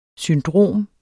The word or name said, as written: syndrom